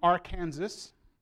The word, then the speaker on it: Arkansas: So, well, we're not in Arkansas, but at the end of the state's name, that hard S is not allowed to be said. Arkansas is legally supposed to be pronounced Arkansas.